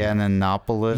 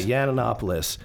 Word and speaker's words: Yiannopoulos: Yiannopoulos. – Yeah, Yiannopoulos.